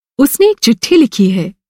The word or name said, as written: चिट्ठी